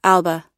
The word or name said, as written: alba